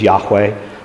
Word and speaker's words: Yahweh: But Jehovah is really an English creation. Most likely the pronunciation is Yahweh, but again, it's not absolutely known.